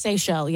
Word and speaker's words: Seychelles: Have you ever heard of the, I believe it's pronounced, is it pronounced Seychelles? – Seychelles, yeah. – The Seychelles, yeah. The island, islands? – S-E-Y, yeah.